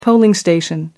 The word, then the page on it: polling station: American English